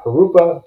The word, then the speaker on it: Jurupa: Today's guest is Steven Hernandez, who is with the uh, Jurupa Community Services District.